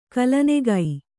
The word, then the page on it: ಕಲನೆಗೈ: kalanegai